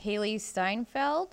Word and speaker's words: Hailee Steinfeld: This is Hailee Steinfeld.